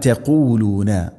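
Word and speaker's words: تقولون: അം തഖൂലൂന, എപ്പോഴും പറയാറുള്ളത് പോലെ തന്നെ തഖൂലൂന, ഖാഫ്, ഖാഫിൻ്റെ ഉച്ചാരണം ശ്രദ്ധിക്കണം. തൊണ്ടയുടെ മുഗൾ ഭാഗവും കഴിഞ്ഞ് ചെറുനാക്കിൻ്റെ ആ ഒരു ഭാഗം.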